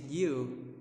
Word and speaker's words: Yu: Hi, my name is Yu. It's weird, right? Because my name's pronounced like you in English.